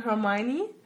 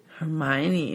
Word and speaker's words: Hermione: Also auf Englisch ist es ja anders geschrieben, ne? – Ach, schreibt man auch anders? – Ja, ja, auf Englisch schreibst du sozusagen Hermione. – Okay. – Und das ist dann Hermione.